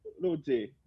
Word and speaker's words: Rosé: É porque a pronúncia em coreana seria Rosé né? Seria J, então é, seria Rosé. Ela fala tipo Rosie, ela mas ela, ela é australiana, ela é australiana, ela cresceu na Austrália.